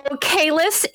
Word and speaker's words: Kalis: So, Kalis is a reference to Star Trek The Next Generation because the leader of the Klingons was known as Kahless, and it is pronounced the same way. It is just spelled differently in this case.